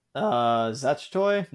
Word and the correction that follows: Zatoichi: yeah, Zatoichi, there you go.